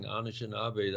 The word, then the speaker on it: Anishinaabe: And Wendigo here, it explained in the paper, is a cannibal monster in Anishinaabe stories who eats uncontrollably. So I was wondering if you could just elaborate a bit on what this means. – Yeah, thank you for correctly pronouncing Anishinaabe.